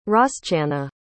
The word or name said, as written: roschanna